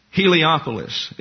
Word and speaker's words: Heliopolis: And it is affirmed of Abraham that he taught astronomy to the Egyptian priests at Heliopolis is the way you pronounce it. It looks like Helio, but it's not. It's Heliopolis.